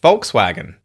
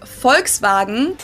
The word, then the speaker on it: Volkswagen: Did go by the full name, it would be pronounced Volkswagen. – Folks, where's the V though? You're using an F. Volkswagen. And the W is the V.